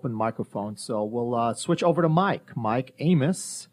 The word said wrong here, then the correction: Ames: I said that right, right? – Ames. – Oh, that's okay. Ames.